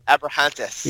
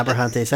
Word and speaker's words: Abrahantes: I always think he looks like Alex Abrahimes, or however you say his name. – Abrahantes. – Yeah, Abrahantes.